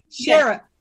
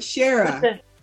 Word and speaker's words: Shara: Shara. Shara. Like Sarah, but Shara.